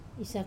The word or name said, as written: sa